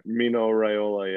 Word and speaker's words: Raiola: But you know, it's funny, as much as we talk bad about Mino Raiola, how do you say it? Raiola? Mino Raiola. – Right. Mino Raiola, yeah.